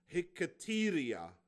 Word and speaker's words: hiketeria: The ancient Greek word for prayers and supplications is the word hiketeria. It's spelled H-I-K-E-T-E-R-I-A.